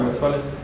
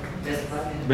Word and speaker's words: Westfalia: ¿Cómo se pronuncia Westfalia? – Westfalia. – Westfalia. No estamos tan mal.